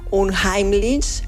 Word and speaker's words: Unheimlich: Se pronuncia, a ver si lo hago bien, Unheimlich, que nombra aquello que es conocido, pero que se vuelve inquietante.